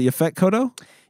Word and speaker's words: Yaphet Kotto: Is it Yaphet Kotto? – Yeah, from Alien and.